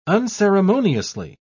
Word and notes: unceremoniously: British English

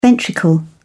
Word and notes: ventricle: British English

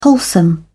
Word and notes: wholesome: British English

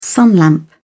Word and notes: sunlamp: British English

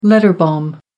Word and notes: letter bomb: American English

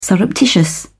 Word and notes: surreptitious: British English

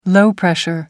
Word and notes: low-pressure: American English

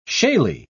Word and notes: shaly: British English

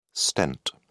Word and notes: stent: British English

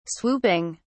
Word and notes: swooping: British English

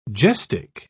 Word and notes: gestic: British English

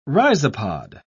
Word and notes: rhizopod: British English

